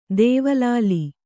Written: ದೇವಲಾಲಿ

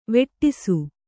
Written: ವೇಡಿಸು

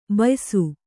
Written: ಬೇಯಿಸು

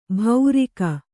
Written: ಭೌರಿಕ

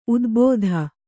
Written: ಊದಬತ್ತಿ

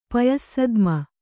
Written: ಪಯಸ್ಸದ್ಮ

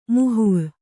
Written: ಮೋಹ

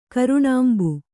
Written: ಕರುಣಾಂಬು

